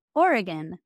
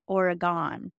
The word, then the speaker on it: Oregon: We out here in Oregon pronounce it Oregon, not Oregon.